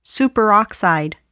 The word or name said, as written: superoxide